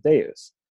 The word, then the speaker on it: Deus: Forrest tells Lily about halfway through the episode that it's the Roman V, so it's actually pronounced like a U, and so it's not called Devs, it's called Deus, which means something.